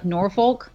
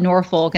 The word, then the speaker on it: Norfolk: It's typically like, you know, if you're not from here, you'll say like Norfolk, you know what I mean? Like that's Norfolk. Like that's how it's spelled, N-O-R-F-O-L-K, but like the locals, it's Norfolk.